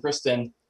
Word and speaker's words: Cristin: And you know, and it's weird when I'll say my name to somebody and be like, hi, I'm Cristin. And they'll be like, nice to meet you, Chris. And I'm like, that's not at all what I just told you my name is.